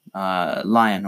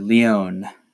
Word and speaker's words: Leon: Speaking of mages with decent defense, the next unit on this list is Lion, or Leon, as I have been told by the game 1,000 times it's pronounced.